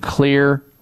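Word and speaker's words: Xlear: My favorite way to do that is through using Clear Rescue Nasal Spray. The Clear is spelled with an X, The X in Clear stands for xylitol.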